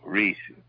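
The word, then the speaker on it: Rhesus: Negative, that's R-H-E-S-U-S, with racist negative. – No, Reese.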